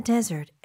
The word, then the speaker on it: desert: desert every night. – 네, 여기 desert 강세 de 쪽에 주는 거 desert가 아니라고 했습니다. – 네, right? 스펠링도 주의하셔야 돼요. D-E-S-E-R-T.